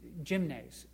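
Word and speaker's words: gymnes (γυμνός): It's a word that comes from the Greek word, which means gymnes, is the way it's pronounced in Greek.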